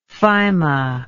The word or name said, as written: phyma